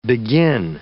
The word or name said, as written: begin